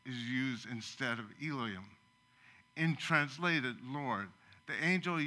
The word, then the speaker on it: Elohim: And one of them is pronounced Elohim, and the other one is pronounced Yahweh.